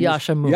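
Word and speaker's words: Yascha Mounk: Our friend Yaksha, is that how you say his name? – Yaksha. – I don't want to be Yaksha Mook.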